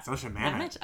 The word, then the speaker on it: Zosia Mamet: Zosia Mamet? – Zosia Mamet.